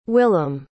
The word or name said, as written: willem